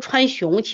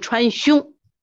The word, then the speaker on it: 川芎: 这个字我们老读川芎，其实准确的读音川芎。